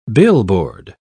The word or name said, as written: billboard